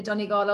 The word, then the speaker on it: Donegal: And as you mentioned, I did a race record, or have a race record and a cycling record for the Donegal Ultra. Your pronunciation was quite good.